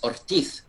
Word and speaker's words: Ortiz: if you pronounce it in the Spanish, but it's Ortiz, Diego Ortiz.